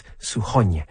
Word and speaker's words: Suchoń: It's S-U-C-H-O-N, and the N has a hatchet on it, which is why it's Suchoń.